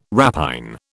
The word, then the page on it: rapine: British English